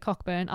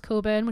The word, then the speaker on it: Cockburn: It's like, it's not at all, but you know, like the surname, like Cockburn. It looks like Cockburn. I think it's pronounced Coburn, which makes more sense.